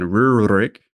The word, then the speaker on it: Rurik: So this is Rurik, or maybe to approximate the Russian pronunciation, Rurik.